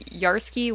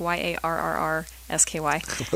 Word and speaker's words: Yarrrsky: I'm Yarsky with three R's. So that's Yarrrsky.